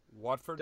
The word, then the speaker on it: Watford: That's how you say it? Watford? – Watford. – W-A-T-F-O-R-D.